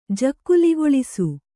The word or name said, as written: ಜಕ್ಕುಲಿಗೊಳಿಸು